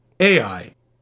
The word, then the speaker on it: Ai: Back in the 70s, a young couple came to him wanting to have their newborn son baptized AI, pronounced like the two letters, emphasis on the first. So, AI.